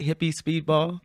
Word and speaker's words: Hippie Speedball: Like, did you say that right? Hippie Speedball? I'm like, yes. – Yeah. – Yes, I did.